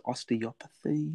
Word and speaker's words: Osteopathy: As a practitioner in the art of how do you pronounce it? Osteopathy? – That's it. Yeah, good work.